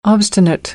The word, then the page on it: obstinate: American English